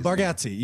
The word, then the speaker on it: Bargatze: It came out today about Nate Bargatze. – Oh yeah. – Am I saying that right? – Yeah. – Nate Bargatze. I don't know how you say it. No, Bargatze, you got it.